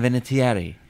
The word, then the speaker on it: Vinatieri: Adam Vinatieri.